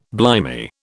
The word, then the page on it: blimey: British English